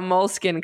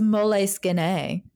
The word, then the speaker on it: Moleskine: I thought it was Moleskine. – That's if you're Italian. Actually, do you know that I researched this because I researched it, and apparently they officially say that you can pronounce it however you want.